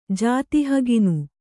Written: ಜಾತಿಹಗಿನು

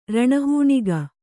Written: ರಣಹೂಣಿಗ

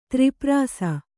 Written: ತ್ರಿಪ್ರಾಸ

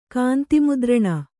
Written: ಕಾಂತಿಮುದ್ರಣ

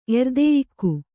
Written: ಎರ್ದೆಯುಗಿ

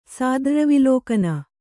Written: ಸಾದ್ರ್ರವಿಲೋಕನ

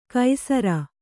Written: ಕೈಸರ

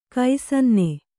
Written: ಕ್ಷಣನ

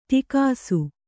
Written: ಟಕ್ಕಿಸು

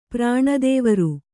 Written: ಪ್ರಾಣದೇವರು

